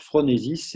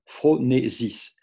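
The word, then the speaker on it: phronèsis: Alors phronesis, c'est P-H-R-O-N-E, accent E, hein, phronesis, S-I-S.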